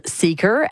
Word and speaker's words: Seekr: That's spelled Seeker, S-E-E-K-R.